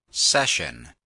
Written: session

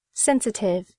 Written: sensitive

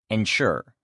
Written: ensure

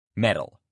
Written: metal

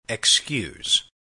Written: excuse

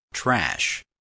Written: trash

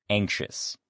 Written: anxious

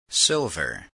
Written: silver

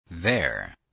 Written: there

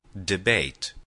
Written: debate

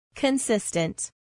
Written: consistent

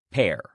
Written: pair

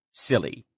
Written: silly

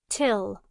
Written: till

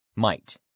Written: might

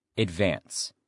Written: advance

